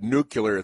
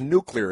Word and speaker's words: nuclear: The word is nuclear. It's an important word. It's nuclear. It's unclear to me why you would pronounce it nuclear when the word is obviously nuclear.